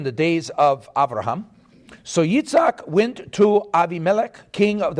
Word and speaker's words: Yitzhak: So when I say Avraham, yours is Abraham. And when I say Yitzhak, okay, you know it's Isaac. So I'm pronouncing it the way it was pronounced in Hebrew.